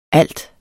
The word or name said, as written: alt